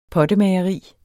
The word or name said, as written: pottemageri